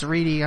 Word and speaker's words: Ready: It's spelled Ready, but it's Reedy.